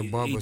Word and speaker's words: баба: Да, так что и слово здесь действительно, еще раз подчеркнем, произносится так же, как и русское слово «баба», то есть с ударением на первый слог. Никаких ром-баба быть не может.